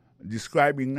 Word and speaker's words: describing: Alors, describing, nan, se participe présent, verbe to describe, sa nou ka wè la, sa nou constate la, nou ka constate que li a prononcé mwen de describing, accent tombe, sous deuxième syllabe là ça veut dire moi ça a trois syllabes describing so,